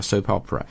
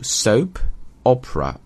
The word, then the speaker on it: soap opera: 晚10点档的电视剧叫 soap opera。那么注意一下这个单词的读音，有一个连读 soap opera, opera 实际就歌剧的意思，但是连读 soap opera, soap opera。